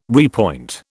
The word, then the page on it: repoint: British English